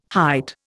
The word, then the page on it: hight: Indian